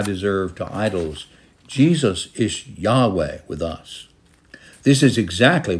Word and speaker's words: Yahweh: In the older translations, it was pronounced Jehovah. We know more accurately, it should be pronounced Yahweh.